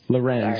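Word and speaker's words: Lorentz: That's Lorenz. – Sorry. Yeah, the T is silent. They just threw that in there for fun. It's Max Lorenz, M-A-X, L-O-R-E-N-T-Z.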